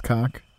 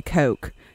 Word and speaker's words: Koch: I want to say Coke, just so that people know that it's spelled the same as the Koch brothers, but I'm going to stop myself.